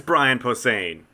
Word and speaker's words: Brian Posehn: Is it Brian Posehn? – It is Brian Posehn. – Oh, I said his name wrong, and we did meet him.